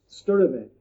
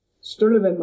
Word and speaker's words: Sturtevant: My website is James Alan Sturtevant. And Alan is spelled kind of interesting. It's A-L-A-N. Sturtevant, my last name, is spelled S-T-U-R.